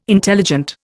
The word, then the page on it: intelligent: Indian